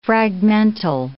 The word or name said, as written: fragmental